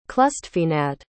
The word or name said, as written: clustfeinad